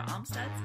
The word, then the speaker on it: Amstutz: And you can find me on all social media platforms at Sandra Amstutz. My last name is spelled A-M-S-T-U-T-Z.